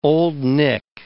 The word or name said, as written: Old Nick